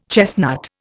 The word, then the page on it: chestnut: Indian